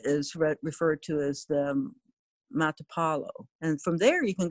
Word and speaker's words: Matapalo: Surfers like to go to Playa Matalpo, and that's M-A-T-A, I mispronounced it, Playa Matapalo. – Matapalo. – That's Playa Beach, and that's spelled M-A-T-A-P-A-L-O.